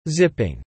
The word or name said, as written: zipping